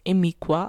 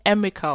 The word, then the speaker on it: Emiqua: Es wird nicht Emika ausgesprochen, für all diejenigen, die sich das immer gefragt haben, ob das ein K ist am Ende mit diesen drei Buchstaben Q, U, A. Aber nein, es ist ein Qua, Emiqua.